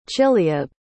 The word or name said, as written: chileab